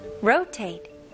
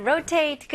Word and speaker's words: rotate: R로 시작합니다. 네, R하고 L 소리는 확실하게 구별해 드려야 돼요. R 발음은 혀가 뒤로 당기는 느낌이고요. 절대로 혀가 천장을 닿지 않아요. 닿는 순간에 L 소리가 나와요. 그래서 rotate 이렇게 나오면 안 됩니다.